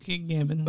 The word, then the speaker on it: Gambon: Sir Michael Gambon. Am I saying that right? – Yeah. – Gambon? – I think Gambon.